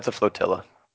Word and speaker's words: flotilla: I pronounced Fortilla like tortilla. – It's a flotilla.